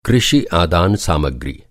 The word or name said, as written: आदान